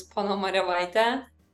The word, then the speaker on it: Ponomariovaite: Thank you so much for having me here today, and the last name is Ponomariovaite.